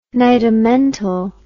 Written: nidamental

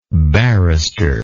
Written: barristerial

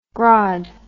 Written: grod

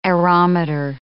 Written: aerometer

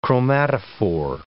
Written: chromatophore

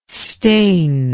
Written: steen

